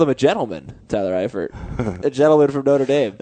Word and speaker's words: Eifert: I hope that, how do you pronounce his name? – Eifert. Tyler Eifert.